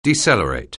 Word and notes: decelerate: anglais

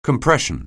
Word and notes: compression: anglais

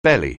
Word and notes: belly: anglais

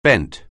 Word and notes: bent: anglais

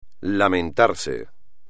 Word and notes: lamentarse: espagnol

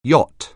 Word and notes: yacht: anglais

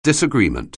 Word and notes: disagreement: anglais

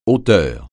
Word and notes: auteur: francais

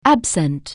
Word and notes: absent: anglais

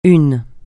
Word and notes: une: francais

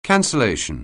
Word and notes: cancellation: anglais

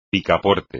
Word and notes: picaporte: Spanish

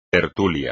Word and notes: tertulia: espagnol

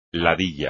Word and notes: ladilla: Spanish

